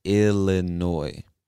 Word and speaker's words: Illinois: Now, if you're reading this or if you've ever read the word, you'll see that the word ends with an S, but we do not pronounce that S. It is silent. Say Illinois.